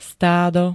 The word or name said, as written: stádo